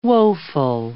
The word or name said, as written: woful